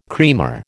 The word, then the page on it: creamer: American English